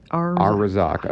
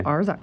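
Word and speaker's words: Arrazaaq: It's A-R-R-A-Z-A-A-Q. – Arzak. Okay. – Yeah, Arzak.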